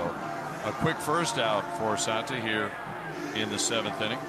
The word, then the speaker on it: Alimber: I believe he pronounces it Santa, but A-L-I-M-B-E-R.